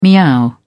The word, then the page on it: meow: British English